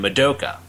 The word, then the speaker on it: Madoka: But head over to your Netflix search bar and type in Magicka, magic with an A, and then Madoka, which is spelled exactly how it sounds, M-A-D-O-K-A.